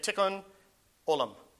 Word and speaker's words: Tikkun Olam: I think it's how you say it, Tikkun Olam. T-I-K-K-U-N Olam, O-L-A-M.